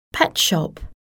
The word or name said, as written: pet shop